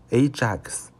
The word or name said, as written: ajax